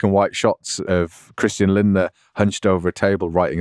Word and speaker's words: Lindner: Good luck, Lindner. I got the name right, didn't I? – Yeah, you did.